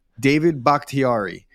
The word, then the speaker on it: Bakhtiari: How do you pronounce his name? Bakhtiari? – David Bakhtiari.